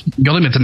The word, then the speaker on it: Guilliman: Under Gilliman, I keep reading this. In my defense, Gilliman's name is spelled with a U. All right. It's just pronounced Gilliman because God hates people who can't read.